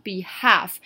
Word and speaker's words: behalf: Behalf，它的 H-A-L-F 的 L 呢，其实是有一点像没有发音，所以是 behalf，会直接念 half 的音，不会有一个 half 的感觉，所以是 behalf 是代表。